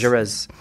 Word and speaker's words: Jaurès: And of course, there were those great leaders like Jaurès here. Is that how you pronounce it? – Jaurès.